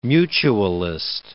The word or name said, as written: mutualist